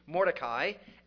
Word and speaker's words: Mordecai: There's a man in our Bible called Mordecai, or to take the Hebrew pronunciation, Mordecai, and he got passed over for a promotion.